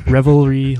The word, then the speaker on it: Revelry: Yeah, Revelry Labs. I have to separate it into three syllables.